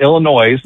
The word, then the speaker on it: Illinois: Well, it's not Illinois. There's no, you don't pronounce the S at the end.